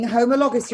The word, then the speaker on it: homologous: So your uh, non um, homoga, I keep saying it wrong. It's really difficult to say. Homologus and joining homologous, your non homologous end jo- uh, and joining engine is working normally.